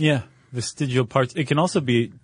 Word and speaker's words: vestigial: Vestigial organs. – Nope. – You have to say it like you're all like you're eating a meatball. Vestigial.